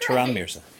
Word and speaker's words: Turan Mirza: Turan Mirza.